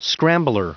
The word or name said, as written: scrambler